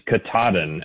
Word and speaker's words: Katahdin: And I'm just going to, off the top, say that the pronunciation is Katahdin, actually.